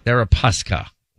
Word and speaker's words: Deripaska: Deripaska.